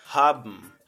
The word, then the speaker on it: haben: Então note que a pronúncia do haben aqui ele fica haben, haben igual no zibm. Toda palavra que terminar em B e N vai ter esse som de bm, bm, haben, zibm, fabm, e a menina depois ela fala, responde, né?